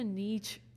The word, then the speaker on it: niche: It's also such a niche, like, is that the word? Is that how you say it? Niche? – Yes.